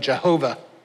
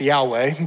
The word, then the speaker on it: Yahweh: Jehovah. I didn't tell him it's actually pronounced Yahweh, but so even if he was right about the verse, he was still getting it wrong.